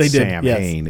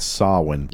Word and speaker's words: Samhain: And incidentally, they pronounce it wrong. It's not Samhain. – Yes. – It's Samhain. Like all fucking Gaelic words, it's completely spelled in a ridiculous way.